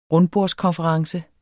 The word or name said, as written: rundbordskonference